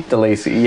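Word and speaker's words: Delacey: How do you pronounce this girl's name? DeLacy? – DeLacy.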